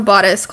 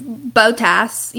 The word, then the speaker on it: Bottas: And if any of you watched Drive to Survive, you would have been blessed with the ass flash we got of Bottas climbing into his sauna. – Reddit endearingly calls him Big Booty Bottas. – Love it. And a quick disclaimer, we pronounce Valtteri Bottas, because we're American.